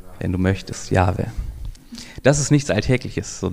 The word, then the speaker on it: Yahweh: Und es ist so, dass dieser Name so wenig ausgesprochen wurde, dass man heutzutage gar nicht mehr so genau weiß, wie der eigentlich ausgesprochen wird, das auch ein bisschen lustig ist. Aber wahrscheinlich heißt dieser Name Yahweh.